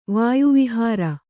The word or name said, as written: ವಾಯುವಿಹಾರ